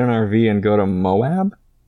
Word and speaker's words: Moab: Moab. – Oh, okay. – Yeah. Moab.